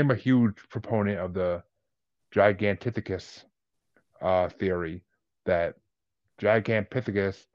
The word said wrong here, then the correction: Gigantopithecus: however you want to pronounce it, was been around since... – Gigantopithecus.